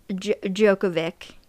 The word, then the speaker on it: Djokovic: It's tennis player Novak Djokovic.